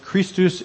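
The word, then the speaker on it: Christus (Χριστός): Christ is the Greek word, and it's actually pronounced Christus in Greek, or at least it was at the time.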